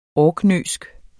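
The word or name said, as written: ORKNØISK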